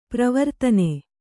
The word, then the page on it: ಪರವರ್ತನ: paravartana